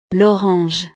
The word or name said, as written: l'orange